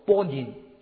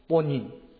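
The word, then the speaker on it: 波 宁 (בונים): 建造的人在希伯来文里面发音叫做波宁，波宁，波宁，这个发音啊，建造人的发音是波宁。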